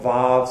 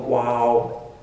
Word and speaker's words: Vav: It's sometimes called Vav, sometimes it's called Wow. There's two different pronunciations of it, but it's the sixth letter of the Hebrew alphabet.